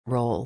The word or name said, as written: role